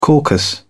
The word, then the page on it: caucus: British English